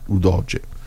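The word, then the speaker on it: Udogie: Sono usciti Ken e Di Marco e Reteghi, sono entrati ovviamente Ken per Reteghi e Destiny Udoge, come si pronuncia? – Udoge per Di Marco.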